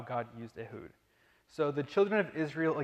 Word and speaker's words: Ehud: And I've also always grown up saying Ehud, but apparently that was wrong. I found out as I studied this in depth. The correct pronunciation is Ehud.